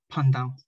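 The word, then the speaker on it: Paj ntaub: Pandao, pronounced Pandao. Pandao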